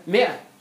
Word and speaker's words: مئة: مثلا مئة بنكتب مئة كده الألف دي ما بتنطقها صحيح ممكن تكتب كده مئة الألف اللي بالألف ده اللي ماشي مش الأقوى الأشهر يعني.